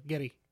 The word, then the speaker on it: Geddy: And apparently the nickname Geddy, as TJ pointed out in the Lost episode, came because the way his mother pronounced it was Geddy, Geddy, Geddy. So it just kind of adopted the double D instead of the R.